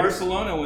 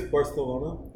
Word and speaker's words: Barcelona: Barcelona when the game happened. That's I believe it's Barcelona. There's gotta be a little bit of a lisp.